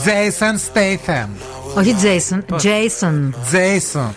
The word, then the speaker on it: Statham: Δεν είναι statham είναι Statham. Και μα στέλνει και μια ηχογράφηση τώρα για να ακούσουμε σωστά την, την προφορά. Γιατί Ακούστε τι είπαμε. – Η σωστή προφορά του ονόματο είναι Jason Statham.